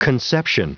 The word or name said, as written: conception